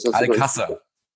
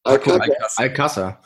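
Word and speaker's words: Alcácer: Übrigens, wie wird der denn nur ausgesprochen? – Al-Kazar. – Al-Kazar. – Ja. – Ich dachte Al-Kazar, aber gut. – Ja. – Dann haben wir wieder was gelernt.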